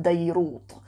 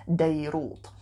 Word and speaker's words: Deirut: It doesn't look especially big when I've seen it on the maps, but it's called Deirut. Sounds like Beirut, but it's not spelled with a ta, it has a ta on the end. Deirut.